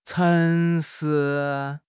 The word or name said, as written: cheng2 shi2